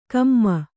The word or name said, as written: ಕೊಮೆ